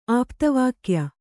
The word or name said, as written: ಆಪ್ತವಾಕ್ಯ